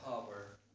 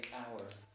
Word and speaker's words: hour: Again, this word, you have to just omit the H in pronunciation, like to be honest, okay? Like that. Or the word hour, okay? Again, you don't say hover, say hour.